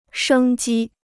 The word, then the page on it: 生机: shēng jī